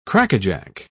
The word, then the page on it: crackajack: British English